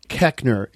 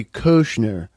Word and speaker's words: Koechner: OE never makes the eh sound in the English language. – Yeah. – So our family mispronounces it. Koechner. It should be Koechner.